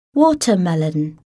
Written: watermelon

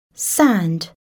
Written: sand

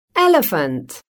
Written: elephant

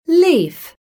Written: leaf

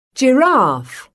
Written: giraffe

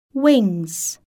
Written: wings

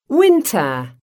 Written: winter

